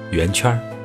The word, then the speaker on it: 圆圈: 要注意啊，发音要发成圆圈的圈，而不是猪圈的圈。而且呢。要用北京方言加上儿化音，圆圈儿。